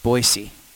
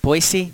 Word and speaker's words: Boise: I'm trying to give people a flavor of Boise. I'm saying it right, right? Boise, not Boise. – See, yes, right? That's right.